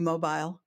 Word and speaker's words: Mobile: It's about an hour away from there, and it is actually Mobile, Alabama. There's a local secret for you.